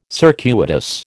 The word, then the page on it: circuitous: American English